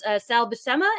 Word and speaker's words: Sal Buscema: And it was the characters created by Jim Shooter and artist Sal Buscema.